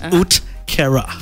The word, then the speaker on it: uhtceare: Utkera. It's spelled U-H-T-C-E-A-R-E. But it's spelled pronounced Utkera.